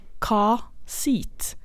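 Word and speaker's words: car seat: Jo, der er det sånn at R-en den skal bare uttales hvis den kommer framfor en vokal. Og det betyr også overordgrense. Så hvis du tenker da ordet altså bilsete, så kan du si car-seat, altså ingen R.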